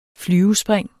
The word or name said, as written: flyvespring